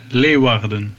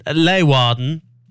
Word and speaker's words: Leeuwarden: I asked Holland to record me the pronunciation earlier. – Princeton Tune, Leeuwarden. – Oh yeah, so Princeton Tune, Leeuwarden. Say it loud and proud.